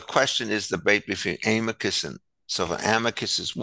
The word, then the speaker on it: amicus: If you took Latin, it would be pronounced amicus. You always emphasize the second to last syllable.